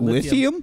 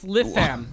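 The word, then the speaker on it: Lytham: That's not Lithium. – Lithium? – It's Litham. Litham, United Kingdom.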